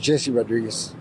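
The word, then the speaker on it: Jessie Rodriguez: My name is Jesse Rodriguez.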